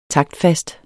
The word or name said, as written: taktfast